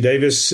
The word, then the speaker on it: Davies: I do have a Twitter account, which is JT Davies, as you Americans like to pronounce it, Davies. JT Davies, as we pronounce it.